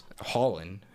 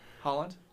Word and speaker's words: Haaland: And so basically what happens is Holland, who transferred to – no, you said it right. – Holland? – Yeah, that's how I said it.